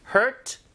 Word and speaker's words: hurt: 자, 발음이 heart가 아니라 hurt. Hurt. 어에 더 가까워요. 들어볼게요.